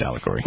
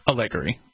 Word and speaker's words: allegory: I think it's pronounced allegory. – What did I say? – Allegory.